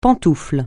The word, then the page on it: pantoufle: francais